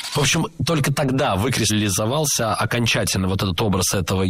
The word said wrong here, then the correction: выкристаллизовался: Было сказано «выкристаллизовался», но это неверно. Правильный вариант – «выкристаллизовался». Ударение падает на первый слог, на приставку «вы».